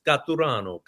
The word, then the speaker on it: Caturano: Catarano.